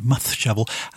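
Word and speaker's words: Mudd Shovel: That lot are a band by the name of Mud Shovel, M-U-D-D-S-H-O-V-E-L, I suppose, being in Wales, I should probably pronounce it Muth Shovel.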